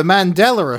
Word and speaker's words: Mandela: The Mandela effect, as it should be pronounced, is based on everyone remembering Nelson Mandela dying in prison.